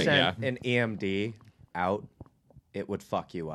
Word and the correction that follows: EMP: EMP. – Did I say that wrong? – You said yeah. – D, but yeah. – EMP. – It's P? Okay. EMP.